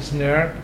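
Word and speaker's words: snRNP: You pronounce it SNRP.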